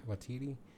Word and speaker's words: Waititi: Waititi? – Waititi.